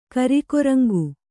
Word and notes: ಕರಿಕೊರಂಗು: Kannada

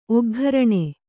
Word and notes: ಒಗ್ಘರಣೆ: oggharaṇe

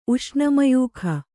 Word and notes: ಉಷ್ಣಮಯೂಖ: Kannada

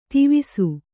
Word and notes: ತಿವಿಸು: Kannada

